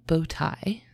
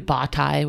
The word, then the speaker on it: bottae: The scientific name is Carina boti. So, not like bow tie, like a thing you would wear. It's like B-O-T-T-A-E. Maybe botie would be a better way to pronounce that.